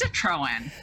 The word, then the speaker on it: Citroen: It's Citroen.